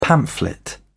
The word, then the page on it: pamphlet: British English